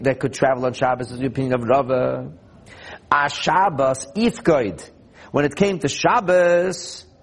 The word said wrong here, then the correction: ipakud: they were commanded, probably ipakud, that's the right way to pronounce it.